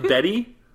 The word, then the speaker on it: Betdie: Okay, B-E-T-D-Y is the name of our timer. – B-E-T-D-I-E? – Bet die, pronounced Betty.